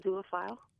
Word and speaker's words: zoophile: Yes, that's how you pronounce it, zoophile.